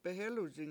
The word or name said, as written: behEluzing